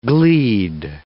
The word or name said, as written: gleed